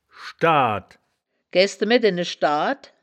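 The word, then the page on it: schdaadt: Wittlicher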